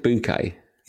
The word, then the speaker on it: Bucket: Is that Hyacinth Bouquet? – Yeah, but it's spelled Bucket, you see.